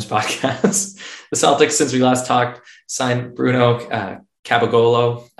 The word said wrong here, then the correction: Caboclo: No, that's what you call Capogolo. You're doing a Sopranos impersonation. It's Caboclo.